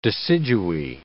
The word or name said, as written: deciduae